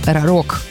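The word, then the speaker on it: Рарог: Я не знаю, як це правильно читається. Рапор. Ра-рок. Ра-рок. Так. Це найправильніше прочитання.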